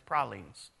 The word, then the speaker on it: pralines: Yeah, you can watch them make beignets just a little bit down from there, You can watch them make pralines, and yes, that's how you properly pronounce it. It's pralines. All right? They will correct you.